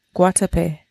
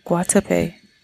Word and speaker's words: Guatapé: Guatapé. Guatapé.